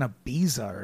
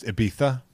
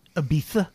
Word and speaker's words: Ibiza: Go to fucking Ibiza or something. – It's pronounced Ibiza. – Ibiza.